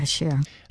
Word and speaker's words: Bashir: Bashir.